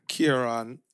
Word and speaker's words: Kieran: Was that with an O or A? – Kieran. – How is that? – My name is spelled with an A. K-I-E-R-A-N. – But that sounds like an O, though.